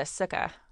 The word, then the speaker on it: sukkah: I want to have a sukkah. – What? – Am I saying it wrong? – No, you're saying it close enough.